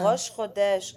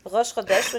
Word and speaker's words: Rosh Chodesh: Oh, and the last thing I am to say is that there is a holiday called Rosh Chodesh. Am I saying that right? I hope so. Chodosh. – Oh, Rosh Chodesh, Oh God, it sounds so good when you say it.